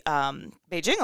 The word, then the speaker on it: Beijing: Anywho, we didn't go to the Torino Olympics, but we went to the Beijing Olympics. – Yes. – When I was secretly pregnant with Ford. – Yes, and nice hard J there. – Well, that's how we're supposed to say it on air.